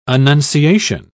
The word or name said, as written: annunciation